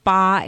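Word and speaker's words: ba (八): Well, 8 in Mandarin rhymes with fa. The ba and fa rhyme together.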